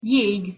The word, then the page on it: jeg: Hungarian